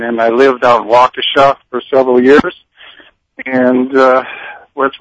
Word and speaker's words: Waukesha: Now, you are from, let's see if I say this correctly, Waukesha, Wisconsin? – Yes, Waukesha, Wisconsin. – Waukesha.